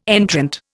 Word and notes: entrant: Indian

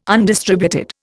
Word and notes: undistributed: Indian

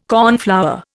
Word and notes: cornflower: Indian

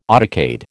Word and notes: autocade: American English

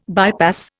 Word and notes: bypass: Indian

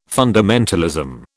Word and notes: fundamentalism: British English

